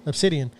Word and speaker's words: Obsidian: I said it wrong. Obsidian.